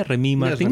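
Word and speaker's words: Rémy Martin: Remy Martín.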